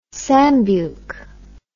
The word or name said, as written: sambuke